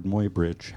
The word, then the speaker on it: Muybridge: I mean, one thing that happens, as you know, right at the beginning, remember Edward Muybridge, however he pronounces his name, I've never known. – That's correct. – Oh, thank you.